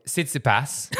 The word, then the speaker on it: Tsitsipas: Tsitsipas.